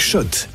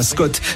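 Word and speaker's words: Schott: Jouer et gagner sur RMC avec Shot, la marque iconique new-yorkaise depuis 1913. Oui, Shot et pas Scott, ça fait des années qu'on prononce mal la marque.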